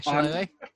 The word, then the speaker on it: shillelagh: I'm going to cast a bonus action shillelagh. I think that's how you say it. – Okay. Yeah, shillelagh.